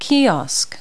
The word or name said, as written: kiosk